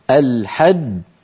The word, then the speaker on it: الحج: تتألف من الحاء المفتوحة ثم بعدها جيم مشددة. ما معنى مشددة؟ يعني فيها جيمان ساكنة ثم جيم مضمومة. الحج حج، هذه الأولى الساكنة مدغمة يعني تخرج بالتصادم، جو هذه مضمومة تخرج بالتباعد ويصاحبها انضمام.